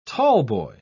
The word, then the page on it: tallboy: British English